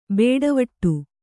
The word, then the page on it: ಬೇಡವಟ್ಟು: bēḍavaṭṭu